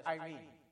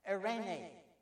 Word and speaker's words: Eirene: We usually refer to it as Irene. That's a beautiful name, isn't it? Irene. Eirene is the Greek pronunciation, and it means calmness.